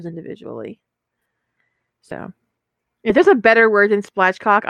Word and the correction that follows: spatchcock: Am I saying it wrong? Spatchcock. There's no L.